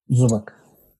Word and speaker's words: Zubac: Zuba Zubac Zubac'ın zubatın Zubak Zubak mı diye mi okunuyordu? – Zubak.